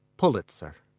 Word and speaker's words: Pulitzer: It's Pulitzer."